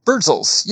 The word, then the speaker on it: Birdzels: And the sculptures that I was talking about at the beginning of our talk, correct me if I'm mispronouncing this, it's pronounced Birdzels? – Birdzels, yes. All right, so I have to give you a little backstory to this piece.